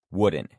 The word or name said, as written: wooden